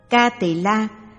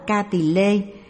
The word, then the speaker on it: Ca Tỳ La: Ca Tỳ La, phiên âm từ Phạn ngữ là Kapila, là tên một bộ luận, cũng đọc là Ca Tỳ Lê hay Kiếp Tỳ La.